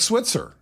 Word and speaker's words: Schweitzer: Schweitzer looks like Switzer.